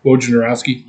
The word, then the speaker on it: Wojnarowski: Yeah, so according to Adrian Wojnarowski.